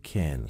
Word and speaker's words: can: can صدای ا میده.